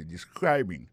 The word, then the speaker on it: describing: Alors, describing là c'est participe présent verbe to describe ça nous carré là ça nous constate là nous ca constater que il y a prononcé mot de describing accent tombé sous deuxième syllabe là ça veut dire mot ça a trois syllabes describing so,